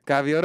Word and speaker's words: Gaviota: Gaviota.